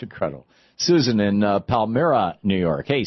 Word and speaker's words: Palmyra: Palmyra, New York, by the way. – Palmyra. – That's how you pronounce it.